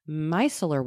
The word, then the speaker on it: micellar: micellar water is just the English translation of the French eau micellaire. So depending on where your product is from, one of those names will likely be listed. Both pronunciations you referenced on the last mini-ep are correct. It just depends on whether you want to say it in the French or English.